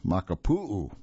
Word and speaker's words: Makapu'u: Now, the Maikupu Lighthouse, or Makapu'u, let me change that pronunciation, Makapu'u Lighthouse on the southeast tip of Oahu, is said to have the largest lighthouse lens in the United States.